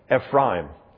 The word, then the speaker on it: Ephraim: I'm trying to learn how to say that right. Ephraim. Craig Hartman insisted that we say it right this summer on the Holy Land tour. Every time I would say Ephraim, he would say, "Don't blaspheme my family name."